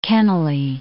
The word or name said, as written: Kennelly